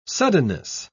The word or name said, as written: suddenness